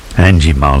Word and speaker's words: Anjimile: Angimile, I think it's how it's pronounced, A-N-J-I-M-I-L-E, and this is called Maker.